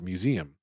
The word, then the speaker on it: museum: Now, it's so funny about the fact that this is how Lauren is claiming she pronounces that word museum, which I, of course, say is three syllables with a second and third rhyming precisely with Liam.